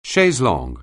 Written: chaise longue

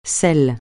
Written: celle

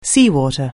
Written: seawater

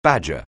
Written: badger

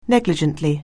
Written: negligently

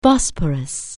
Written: Bosporus

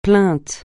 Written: plainte

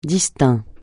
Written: distinct

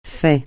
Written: fait

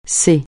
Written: ce

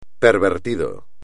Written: pervertido

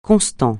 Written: constant

